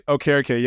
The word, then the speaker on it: Okereke: Okereke, yep. Yeah, it's okay. It's from Nigerian descent. Yeah, it's the correct pronunciation.